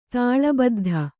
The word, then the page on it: ತಾಳಬದ್ಧ: tāḷa baddha